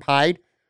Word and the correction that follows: Hyde: Did I say it right the time? Poyer and Hyde. Every time I say those guys' names together, I always say it wrong.